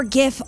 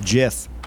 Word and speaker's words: GIF: Also, there's already a panel about the early 2000s called All Your GIF Are Belong to Us. – Yeah, and there's a debate on whether it's GIF or JIF, but... – Well, the guy who invented the format said it's pronounced JIF, but I don't like that.